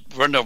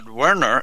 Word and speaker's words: Wernher: But Wernher von Braun And his first name was not Werner, it was Wernher.